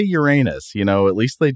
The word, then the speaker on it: Uranus: If you listen to astronomers and planetary scientists talk about this planet, most often you will hear them pronounce it Uranus. Basically the word urine and then the word us.